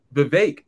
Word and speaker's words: Vivek: But afterwards, although Vivek, that's what he calls it, Vivek rhymes with cake. That's how you kind of should know how to say his name.